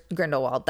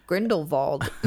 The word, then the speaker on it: Grindelwald: Keep saying Grindelwald. That's how they say it in the Harry Potter movies. And now that we've moved to Fantastic Beasts, suddenly they're like, Grindelwald. I'm like, now I have to have a German accent.